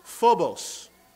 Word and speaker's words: phobos: In the Greek word, it is pronounced phobos. That sounds familiar. It is where we get our English word phobia.